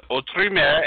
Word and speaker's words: Autre Mer: I butchered the name, so anybody who's listening, I called it Outreamer, which is probably a very American extension of it. It's true pronunciation is Autre Mer, and I'm sure if you walked into a comic book shop and asked for Outreamer, they're going to recognize it anyways.